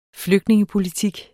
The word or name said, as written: flygtningepolitik